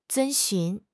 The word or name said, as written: 遵循